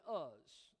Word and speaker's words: Uz: He lived in Uz, okay, or Uz, some people pronounce it.